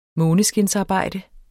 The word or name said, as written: måneskinsarbejde